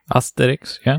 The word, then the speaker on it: Asterix: We're talking about Asterix. Is that how it's pronounced? Asterix? – Asterix, yeah. – Asterix, okay. And whether or not you're familiar with Asterix,